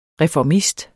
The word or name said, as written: reformist